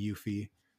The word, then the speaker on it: Yuffie: I think it's probably Yuffie. I pronounce it Yuffie because of the musical artist Uffie.